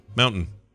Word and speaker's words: mountain: Muntain. – Oh, mountain. – Okay, yeah, you do the Colorado thing, which is to, you don't really pronounce that middle T.